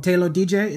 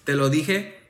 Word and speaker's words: Te lo dije: You made the Spanish EP, I don't want to pronounce it wrong, Te Lo DJ, is that Te Lo DJ? – Te lo dije.